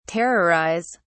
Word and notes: terrorise: British English

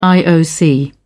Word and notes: ioc: British English

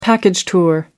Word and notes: package tour: American English

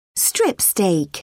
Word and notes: strip steak: British English

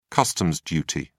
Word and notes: customs duty: British English

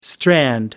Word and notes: strand: American English

